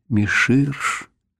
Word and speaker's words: Мессир: Его фамилия была Мессир, а сам он произносил ее как-то вроде Миширш, с неуловимым акцентом, вывезенным из-за железного занавеса, из стран, где все должно быть неуловимо.